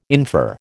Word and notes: infer: American English

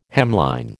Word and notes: hemline: American English

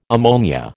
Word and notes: ammonia: American English

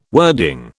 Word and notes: wording: British English

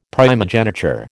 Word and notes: primogeniture: American English

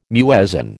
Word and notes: muezzin: American English

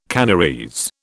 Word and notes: Canarese: British English

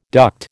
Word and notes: duct: American English

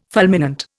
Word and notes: fulminant: Indian

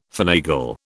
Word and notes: finagle: British English

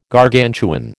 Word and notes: Gargantuan: American English

